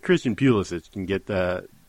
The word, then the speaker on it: Pulisic: By the way, I pronounce it the way you did because I'm a sheep, but it's pronounced Pulisic. Say it with me, Stu. – Pulisic. That's just wrong. That's not... It's Pulisic. That's no, it's not. – Clearly the right... – Yeah, I just can't stress enough that it's not. And if I know how to pronounce this name... – Pulisic? – Pulisic. – So the stress is on the second syllable, so correct. It's almost like saying ballistic? – Yes.